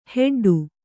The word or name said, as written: ಹೆಡ್ಡಿ